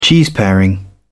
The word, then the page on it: cheeseparing: British English